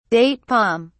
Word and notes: date palm: British English

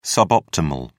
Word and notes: suboptimal: British English

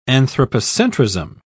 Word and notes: anthropocentrism: British English

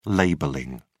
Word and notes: labelling: British English

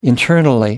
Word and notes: internally: British English

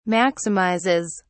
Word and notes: maximizes: British English

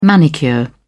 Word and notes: manicure: British English

